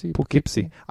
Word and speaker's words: Poughkeepsie: He described himself as the, I'm going to say it, Poughkeepsie seer. Is I saying that right? – Poughkeepsie.